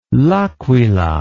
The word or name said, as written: L'Aquila